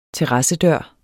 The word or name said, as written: terrassedør